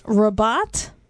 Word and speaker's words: Rabat: And they are in, I believe it's pronounced Rabat, Morocco.